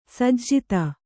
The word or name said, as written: ಸಜ್ಜಾತಿ